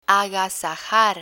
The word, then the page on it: agasajar: spanish